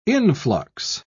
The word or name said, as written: influx